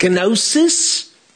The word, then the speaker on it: gnosis: One of those is gnosis. The gamma or G on the front is silent.